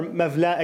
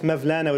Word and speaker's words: Mevlana: Mawlana or Mevlana, I guess Mevlana would be the, I guess, Persian pronunciation.